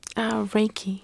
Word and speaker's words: Reiki: One of the treatments you're looking at, I've heard this before, but I don't know anything about Reiki. Am I saying that correctly? – Reiki.